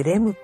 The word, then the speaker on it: REM: さんぽさん、単純にレム睡眠とかけているとか。はあ、レム。 – はあ。 – そういうことか。 – さんぽさん。お散歩さん、お散歩さん、冴えてる。 – お散歩さん、すごい。漢字間違えるけど。あ、本当だ。 REM ってレムって読むんだ。